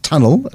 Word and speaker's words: Tunl: And this is one way, just one of the legs, I suppose, of how they will access markets via Matthew Davey and Tunnel. It's pronounced Tunnel, spelled the proper way, but spelled on all of the merchandising is T-U-N-L, focusing on exports entirely from South Africa.